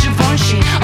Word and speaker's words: Givenchy: Givenchy, it's pronounced Givenchy.